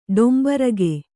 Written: ಡೊಂಬರಗೆ

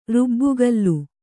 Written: ರುಬ್ಬುಗಲ್ಲು

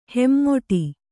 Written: ಹೆಮ್ಮೊಟಿ

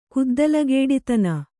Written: ಕುದ್ದಲಗೇಡಿತನ

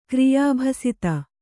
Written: ಕ್ರಿಯಾಭಸಿತ

